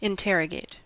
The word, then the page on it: interrogate: American English